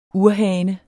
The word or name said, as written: urhane